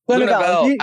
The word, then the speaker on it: Lenouvel: Lenouvel.